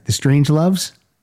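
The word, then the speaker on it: Strangeloves: For example, I'm going to play the original of this song by the Strange Loves. Am I saying that right? – That's right.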